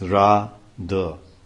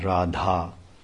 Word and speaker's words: Radha: It's Ra-da, not Radha. But the syllables are there. The Ra, long A, Ra, and then D-H-A. But that's short A instead of long, as in Radha's name.